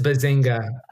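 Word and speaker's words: Bezucha: The Family Stone was written and directed by Thomas Bazooka and, or is it it's pronounced Thomas Bazinga. It's Bazinga. Bazinga. I'm sorry to... Yeah, it's Thomas Bazinga.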